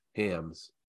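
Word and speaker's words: Hamm: I'm going to say it's pronounced like the beer, Hams.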